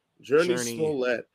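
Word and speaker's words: Jurnee Smollett: Journey Smollett.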